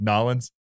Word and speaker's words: New Orleans: and my dad taught me how to say it like a Cajun. it's like Nolans.